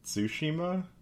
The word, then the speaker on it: Tsushima: Ghosts of Tsushima? Is that how you say that? How do you say that? – Tsushima.